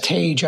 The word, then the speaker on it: Tage: Yes, Tage actually, just like turn the page, Tage.